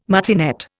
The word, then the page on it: martinet: Indian